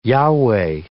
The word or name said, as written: YHWH